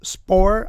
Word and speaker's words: Spoehr: Retired Lieutenant General Thomas Spohr, I think that's how you say his name, S-P-O-E-H-R, the Heritage Foundation spokesperson for military issues, said, quote, "This is the start of a long drought for military recruiting," end quote.